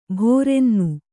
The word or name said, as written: ಭೋರೆನ್ನು